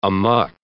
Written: amok

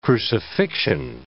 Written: crucifixion

